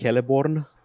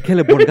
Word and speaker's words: Celeborn: Sau cred că e Celeborn? Cred că e Kappa. se pronunță ca un Kappa, C un... uh, în elfa, în elfa lui Tolkien. Nu știu dacă în fix.